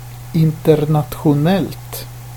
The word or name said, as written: internationellt